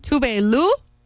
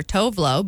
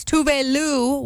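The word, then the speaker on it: Tove Lo: And speaking of new music, I just found out, for one, her name, Tuve Lu. I've always called her Tovlo, but she says that's also acceptable because that's how, well, most Americans say it. It's Tuve Lu with Habits now on MyMix FM.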